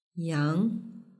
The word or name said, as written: yang